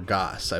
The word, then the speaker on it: Georgas: Georgas. I believe it's Georgas is how you pronounce her last name.